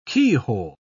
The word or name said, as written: keyhole